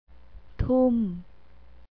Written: toom

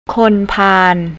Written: khonpan

